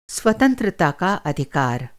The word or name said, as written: अधिकार